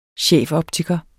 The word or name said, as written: chefoptiker